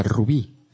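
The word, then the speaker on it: RWBY: RWPY. Se pronuncia Rubí.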